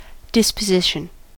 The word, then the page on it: disposition: American English